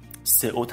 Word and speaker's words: SEO: در زبان فارسی SEO رو سئو تلفظ میکنن